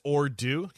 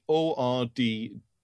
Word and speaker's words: Ordoo: You said that's Ordu. Can you spell that? – Ordu, yeah, ORDOO. – ORD. – Sorry, yeah, ORD. D-O-O.